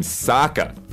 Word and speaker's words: Sokka: However, the character in the show pronounces his name Sokka.